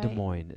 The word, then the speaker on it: Des Moines: Des Moines. It's not Des Moines. It's one city. So it's not plural. – Are you sure on that? – I am positive. – Des Moines. – Des Moines.